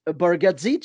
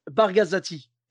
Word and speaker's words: Bargatze: Et donc, lui, son nom, c'est Nate Bargatze, tu vois. Et il y en a, je ne sais pas pourquoi, il prononce Nate Bargazati.